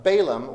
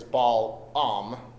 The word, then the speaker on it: Balaam: Now, Balaam, or the right way to say it, the right way to say it is Balaam, but you know, I'm not going to win that battle.